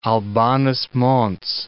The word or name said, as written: Albanus Mons